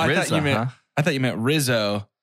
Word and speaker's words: RZA: It's pronounced RZA.